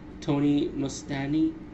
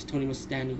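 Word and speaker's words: Tony Musante: excuse me, let me say his name right, Tony Mustani, by the way, yes, his name is Tony Mustani, who plays the revolution leader, who's the good guy, by the way.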